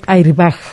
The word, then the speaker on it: airbag: Muy bien, entonces es la palabra airbag, pero se pronuncia airbag. – Airbag, según la recomendación de la Real Academia.